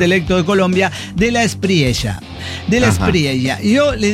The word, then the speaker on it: Espriella: Me dice, es como estrella. – Estrella, sonrisa de una estrella. Estrella del DF mexicano, de la Espriella, de la Espriella.